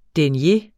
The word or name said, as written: denier